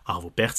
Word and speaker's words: Arvo Pärt: Là, on va aller au 21e siècle avec, vous le savez, l'un de mes compositeurs préférés là aussi, Arvo Pärt, Arvo Pärt, si on veut vraiment le prononcer comme il faut.